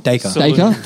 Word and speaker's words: Dacre: And it's Dacre. – Dacre. – I can't say his name. – Think Acre, but with a D at the front.